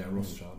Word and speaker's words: Rothschild: is it Rothschild? – Yeah, Rothschild.